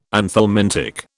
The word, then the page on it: anthelmintic: British English